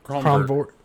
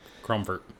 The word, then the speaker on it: Cromvoirt: C-R-O-M-V-O-I-R-T. – Cromvert. – Okay. – Cromvert.